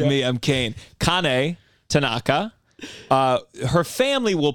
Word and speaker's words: Kane: It's probably pronounced Kane, but yeah.